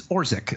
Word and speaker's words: Orzech: And this is what I heard my good friend Jason Wilde say on his podcast this morning, that apparently Matt, I believe it's Orzech is how that's pronounced, O-R-Z-E-C-H, Orzech, you know, who is a bona fide NFL caliber long snapper.